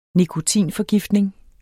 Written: nikotinforgiftning

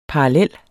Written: parallel

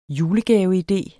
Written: julegaveidé

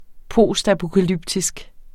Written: postapokalyptisk

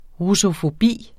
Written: russofobi

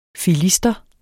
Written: filister

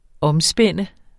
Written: omspænde

